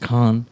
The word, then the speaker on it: Caen: Yeah, because Cannes, there's a town in France, C-A-E-N, that would be pronounced Cannes.